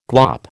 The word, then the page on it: glop: American English